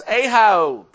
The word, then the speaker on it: Ahio: Sounds like Ohio, but it's Ahio.